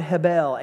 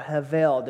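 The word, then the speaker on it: hebel (הבל): Vanity is the Hebrew word hebel, H-E-B-E-L, or hevel, depending on the pronunciation.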